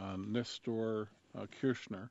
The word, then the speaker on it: Néstor Kirchner: Néstor Kirchner, and I don't know if I got the pronunciation right.